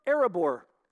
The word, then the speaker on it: Erebor: Erebor.